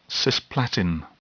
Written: cisplatin

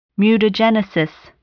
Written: mutagenesis

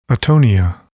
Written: atonia

